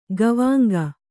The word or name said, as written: ಗವಾಂಗ